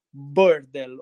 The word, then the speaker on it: burdel: Cosa vuol dire il termine, mh, mm, scusami per la pronuncia, forse sbaglierò, burdel o burdel che scrivete? – Burdel. Burdel. Eh, eh, significa voglia, casino, bordello qua a Rimini.